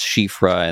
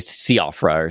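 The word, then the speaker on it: Síofra: but chat constantly reminded me that is pronounced Shifra and that it's Scottish, I think. – It's a Gaelic for like changeling kind of thing. Yeah. – Yeah. Okay. Okay. Yeah. Cause I would say Siofra or something, but anyways, those underground areas were very, very cool to see, just the stars in the sky.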